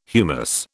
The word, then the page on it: humus: British English